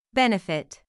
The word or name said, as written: benefit